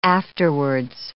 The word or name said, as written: afterwards